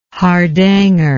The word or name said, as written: hardanger